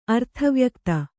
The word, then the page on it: ಅರ್ಥವ್ಯಕ್ತಿ: arthavyakti